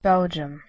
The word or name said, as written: belgium